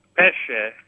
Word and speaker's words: pesce: Pesce and pesca. Pesce as in it's fish, and pesca would be peach.